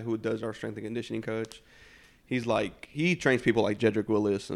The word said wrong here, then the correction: Jedrick Wills: and a bunch of, like, pros. So, he's... – It's Wills. – Jedrick Wills. Sorry.